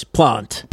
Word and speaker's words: Plante: Maybe it's Planté, because like the goalie... Or is it Jacques Plante? No, it's Plante.